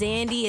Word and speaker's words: Xandi: So y'all can follow, again, my name is Zandy. It's spelled X-A-N-D-I, no Z, start with an X. and you can find me at Instagram at Zandy Official, X-A-N-D-I Official.